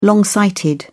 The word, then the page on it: long sighted: British English